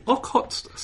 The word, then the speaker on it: Okhotsk: Yes, actually in the Okhotsk Sea.